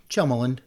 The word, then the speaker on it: Tjelmeland: you really enunciate that second syllable, Tjelmeland. – Yes. – I kind of run it together like Tjelmeland.